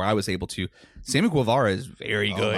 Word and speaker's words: Guevara: Sammy, I always mispronounce his last name. – Sammy Guevara. – Guevara? – Yeah, we're from Texas. You should be able to pronounce Hispanic last names.